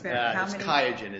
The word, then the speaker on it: Qiagen: Yes, it's terrible. It's Qiagen is how you say it. – Qiagen, thank you.